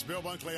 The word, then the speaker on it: Bill Bunkley: That's Bill Bunkley, I should say, if I can pronounce my own name correctly. Bill Bunkley here.